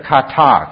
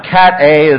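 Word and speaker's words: kata (κατά): Okay, you got to pronounce it kata. Can you do that? Okay, kata. Can you say it? Kata. There, I knew you could do it. Okay, it's not cat A as in cat A and cat B.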